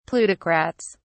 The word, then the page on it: plutocrats: British English